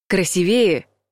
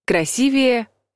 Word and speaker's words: красивее: Красивее — неправильный вариант. Красивее — правильный вариант. Комплименты надо делать правильно. В прилагательном «красивое» ударение падает на второй слог.